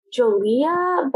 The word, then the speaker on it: Giulia: And I'm not exactly sure how to pronounce her name, but it's like, I looked up the pronunciation and it's like Giulia, but it's like spelled Giulia Tofana.